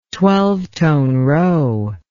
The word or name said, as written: twelve tone row